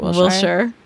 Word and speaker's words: Wilshire: I'm like, that's how you say it, Wilshire.